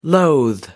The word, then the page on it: loathe: American English